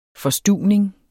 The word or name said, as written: forstuvning